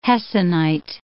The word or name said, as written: hessonite